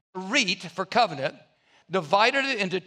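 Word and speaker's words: berit (ברית): This is the word berit. It's pronounced berit.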